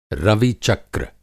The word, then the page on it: रवि-चक्र: Hindi